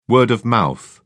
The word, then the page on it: word of mouth: British English